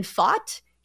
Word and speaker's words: Pfaadt: So uh, I just learned the pronunciation of the Diamondbacks pitcher's name. It is Brandon Pfaadt.